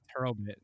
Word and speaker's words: Hurlbutt: So many people try to be polite and they'll say Herbert or like Hurlbutt because they feel like they shouldn't say it the way it's spelled. But that is the way it is.